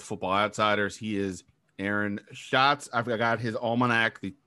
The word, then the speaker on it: Schatz: If you want to follow Aaron on Twitter, he is at FOASCHATZ, but it's pronounced Schatz, Aaron Schatz.